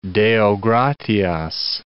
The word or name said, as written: Deo gratias